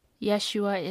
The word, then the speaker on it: Yeshua: Yeshua is the name of how you would pronounce it in Hebrew.